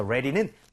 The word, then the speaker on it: ready: 발음할 때 앞에는 입모양 모아주시고요. R 사운드니까 re가 되겠죠. re. 하지만 두 번째 D가 나온 부분에는 flap을 해서 리을 비슷하게 가야 됩니다. 그래서 이런 느낌이에요. 구분을 하면 re, re, re, re. 빠르게 해서 ready, ready.